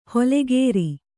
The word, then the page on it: ಹೊಲೆಗೇರಿ: holegēri